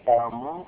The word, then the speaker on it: قاموا: فهنا للفرق بين هاتين الواوين صاروا يكتبون ألف، يرسمون ألف بعد واو الجماعة، فمثلا نقول الرجال قاموا بعد الواو يكتبون ألف لا تنطق، هذه الألف للتفريق بين واو الجماعة والواو الأصلية.